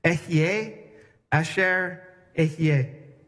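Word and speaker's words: Ehyeh asher ehyeh: I am who I am in Hebrew sounds like this. Ehye asher ehye.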